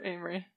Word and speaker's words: Amery: Amory, am I saying that right? – Yep, Amory.